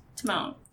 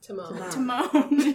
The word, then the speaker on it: Timon: What dance does Timian do to distract the hyenas? Timon. – Timon.